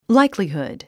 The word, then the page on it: likelihood: láiklihùd; ライクリフド